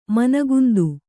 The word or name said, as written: ಮನಗುಂದು